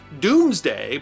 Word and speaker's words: Domesday: And speaking of England's past, William the Conqueror's Domesday Book, from which we have learned so much about England's past, is pronounced Doomsday, but spelled Domesday, D-O-M-E-S-D-A-Y. Not because the Normans couldn't spell, but because dome was pronounced doom before the great vowel shift.